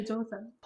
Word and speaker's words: Joseon: But one really interesting book chapter of yours that I read was Hell Joseon. Is that how you pronounce it? Is that okay? – Yeah, Hell Joseon.